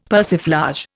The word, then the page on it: persiflage: Indian